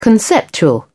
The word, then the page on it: conceptual: British English